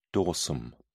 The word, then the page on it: dorsum: British English